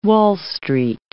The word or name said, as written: Wall Street